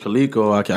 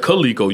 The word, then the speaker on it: Coleco: You said Coleco. You put that shit in. You broke that shit down into three syllables. Coleco. Like, no, niggas, Coleco.